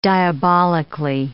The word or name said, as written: diabolically